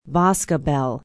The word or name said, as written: boscobel